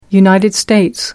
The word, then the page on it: united states: British English